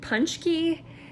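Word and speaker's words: pączki: His best friend is Campbell Sellers, who happens to be a real estate agent, and even the detective surname, I believe you pronounce it Punchki, and those are donuts found in Polish cuisine.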